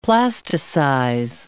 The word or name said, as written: plasticize